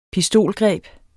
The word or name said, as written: pistolgreb